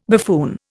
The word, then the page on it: buffoon: Indian